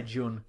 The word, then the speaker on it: Jun: J-U-N. How do you pronounce it? – I say Jun.